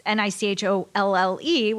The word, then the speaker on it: Nicholle: So it's Nicholle, which people say Michelle, Danielle.